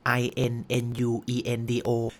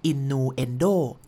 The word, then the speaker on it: innuendo: วามหมายในตัวเองครับก่อนจะไปลงนะอธิบายนะขอพูด innuendo ไปด้วยแล้วกันนะครับผม i n n u e n d o จริงๆมันต้องควรต้องอ่านว่า innuendo